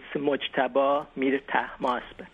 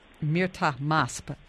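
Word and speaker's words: Mojtaba Mirtahmasb: I know Mr. Panahi's co-director, his name is a little tough to pronounce, but it's Mojtaba Mirtamasab. – Mirtamasab.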